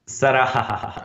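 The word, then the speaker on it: Sarahah: Sarahaha, come si pronuncia? Sarahaha. Ah, perfetto.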